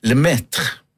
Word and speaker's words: Le Maître: For det sånn at der spiller bandet Le Maître. Jeg tror du uttaler sånn. – Veldig bra uttale.